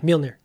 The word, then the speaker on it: Mjolnir: And then I go into the Wildwood spec Mjolnir. Is that correct? – Yeah, Mjolnir. Yeah, no, that's how it's pronounced.